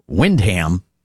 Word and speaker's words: Windham: But in New Hampshire, the people in Windham like to pronounce it Windham and not Windham.